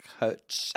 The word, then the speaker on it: Koch: I think I said coach on the but it's spelled like K-O-C-H, right? – Yeah.